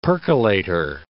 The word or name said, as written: percolator